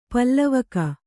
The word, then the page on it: ಪ್ಲಾವಕ: plāvaka